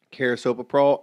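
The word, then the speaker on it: carisoprodol: Carisoprolol and lorazepam.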